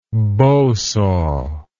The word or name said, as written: bow saw